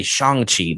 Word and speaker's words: Shang-Chi: We just got a new Shang-Chi trailer, or should I say Shang-Chi. That's how they've been pronouncing it.